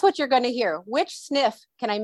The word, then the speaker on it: SNF: A SNF is spelled SNF, and that stands for skilled nursing facility. – Okay. Nursing facility, a SNF.